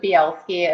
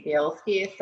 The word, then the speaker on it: Bielski: That is the popular pronunciation. It's we pronounce it Bielski in my family, but I think it probably should be Bielski.